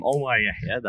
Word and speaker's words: Omar Yehia: So I'm Omar Yehia.